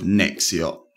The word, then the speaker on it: Nexxiot: So tell me about the path that led you to become the co-founder of Nexiot.